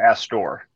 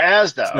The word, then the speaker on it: Asda: Is it Asda or Astor? – Asda. – Oh, Asda. No. Okay. – Asda. – Completely mispronounced. Okay. – Asda. – Yeah, I did not hear Asda, but now I do. – It's two syllables.